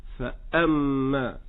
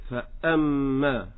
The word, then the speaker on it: فأما: إنما الصحيح مع الهمزة أن تكون همزة قطع ينقطع الصوت فيها هكذا: فأما، فأما.